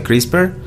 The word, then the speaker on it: CRISPR: se pronuncia CRISPR, CRISPR Cas9. Ya se escribe C R I S P R CRISPR. ¿Qué? CRISPR. CRISPR. – Sin la E, ¿no?